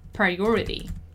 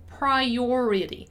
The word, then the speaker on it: priority: Let's just take the chance once again to work on the pronunciation of the word priority, because it has those two R's in the middle. So priority, priority, priority, on high priority, put the project on high priority.